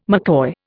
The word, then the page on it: McCoy: Indian